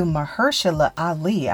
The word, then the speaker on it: Mahershala Ali: So basically, Wesley Snipes gives his approval to Mahershala Ali.